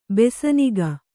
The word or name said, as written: ಬೈಸನಿಗೆ